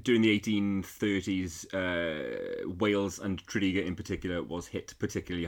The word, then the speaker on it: Tredegar: Don't be tempted to call it Tredegar. Lots of out-of-towners do. It's Tredegar. Tredegar. There you go.